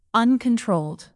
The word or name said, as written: uncontrolled